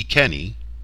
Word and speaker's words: Iceni: These names will be pronounced using the European continental rules for vowels and a hard C throughout, the Ikeni and Boadicea.